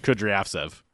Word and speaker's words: Kudryavtsev: Kudryavtsev. That wasn't bad. – Yeah, I thought you were close. – Yeah, close. That was like in the ballpark.